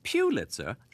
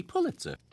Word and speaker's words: Pulitzer: Although many people pronounce it Pulitzer, the correct pronunciation is actually Pulitzer.